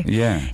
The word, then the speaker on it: Ye: Well, it's yay, like Kanye. But I like it to say it like as in our segment that we do on the show, yay or nay. – Yeah. – Yay. – Is it spelled Y-A-Y? – No, it's Y-E.